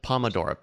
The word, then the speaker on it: Pomodoro: Is it Pomodoro or Tomodoro? How is it pronounced? – Pomodoro, P-O-M-O.